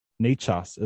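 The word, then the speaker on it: Nečas: N-E-C-A-S, Nechas, is I think how they pronounce it.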